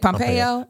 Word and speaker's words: Pompeo: Is that how you say it? Pompeo? Uh-huh.